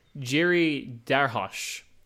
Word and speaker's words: Jiří Drahoš: and newcomer Jiří Drahoš. I believe you really pronounce the H in it.